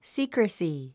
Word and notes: secrecy: síːkrəsi; スィークラスィ

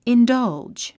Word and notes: indulge: indʌ́ldʒ; インダァウジ

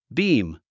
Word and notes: beam: bíːm; ビーム

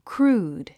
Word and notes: crude: krúːd; クルード

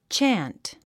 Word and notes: chant: tʃǽnt; チャント

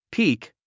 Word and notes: peak: píːk; ピーク

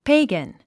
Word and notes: pagan: péiɡən; ペェイガン

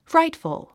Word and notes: frightful: frǽitfəl; フライトフゥ

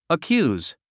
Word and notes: accuse: əkjúːz; アキューズ

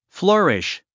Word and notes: flourish: flə́ːriʃ; フルゥリッシュ

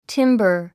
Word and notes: timber: tímbə r; チンバァ

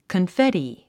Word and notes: confetti: kənféti; コンフェティ

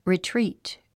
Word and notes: retreat: ritríːt; リトリート

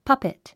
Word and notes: puppet: pʌ́pit; パペト

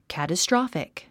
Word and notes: catastrophic: kæ`təstrάfik; キャテストロォフィック

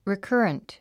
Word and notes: recurrent: rikə́ːrənt; リィカァラント

